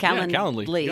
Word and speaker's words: Calendly: But we, you know, in my Calendly, did I say that right? – Calendly. – Got it.